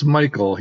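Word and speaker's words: Michel: It's Michael. He says Michael. You have to ask his parents why they spelled it that way. – That's yes. Right. It is spelled a little differently. It's M-I-C-H-E-L.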